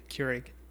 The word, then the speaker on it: Keurig: Keurig? – Keurig.